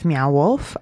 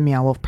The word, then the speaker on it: Meow Wolf: And if you haven't heard of Meow Wolf, you may... – Is that spelt how it's pronounced? – Meow, like the sound a cat makes, and wolf, like the not a dog.